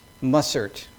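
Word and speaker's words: Mussert: Many here perhaps would know the name, and I might not get this correct, Anton Adrian Mausert. Is that correct? No? What's the proper pronunciation? Mausert. Okay. That's my accent.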